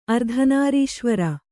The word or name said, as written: ಅರ್ಧನಾರೀಶ್ವರ